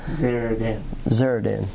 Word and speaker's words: Nebuzaradan: Nebuzaradan. – Yeah, when you read it, I thought, because we're all having trouble with that, Nebuzaradan.